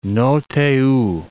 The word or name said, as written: Roh Tae Woo